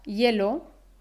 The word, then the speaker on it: hielo: Cuando pronuncio la H en la palabra hielo, hielo, ¿es la H de hielo realmente muda? En este caso la H sí se pronuncia. En realidad decimos hielo, hielo. Por ejemplo, si estás en una cafetería y pides un café con hielo, un café con hielo, escuchamos ese sonido y.